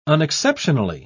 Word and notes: unexceptionally: British English

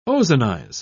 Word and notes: ozonize: British English

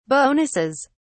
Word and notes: bonuses: British English

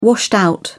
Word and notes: washed out: British English

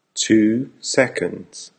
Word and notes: 2 seconds: American English

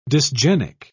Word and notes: dysgenic: British English

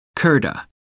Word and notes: kurta: American English

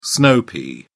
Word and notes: snow pea: English; British English

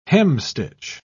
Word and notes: hemstitch: British English

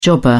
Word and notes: jobber: British English

